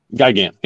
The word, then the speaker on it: Gigan: I always mispronounce this one. I think, is it Gigan? – Gigan, yeah.